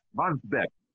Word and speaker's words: Wandsbek: and Brunsbrück. Ahu, it was called. Those were three big German cities. – Right, Wandsbek. – Mm-hmm. I said it wrong. Wandsbrück? Wandsbek. Uh-huh.